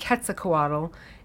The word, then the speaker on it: Quetzalcoatl: Quetzalcoatl if it's Spanish, and Quetzalcoatl if it's Nahual, which is the language that the name came from, Nahual language.